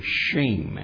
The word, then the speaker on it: shem: The word name in Hebrew is the word shem. It's pronounced that way.